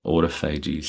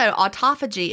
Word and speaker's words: autophagy: And also, autophagy is not – sorry, I said the American way, autophagy. Sorry. – You're, I love your – I love your Australian pronunciation. That really is how people say it in Australia, anyone who's listening.